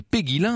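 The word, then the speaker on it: Puyguilhem: Il est marquis de, alors ça s'écrit Puy-Guillem, mais ça se prononçait Péguilin à la cour, et il était comte, un jour il sera duc de Lauzin.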